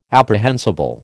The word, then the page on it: apprehensible: American English